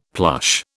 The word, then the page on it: plush: British English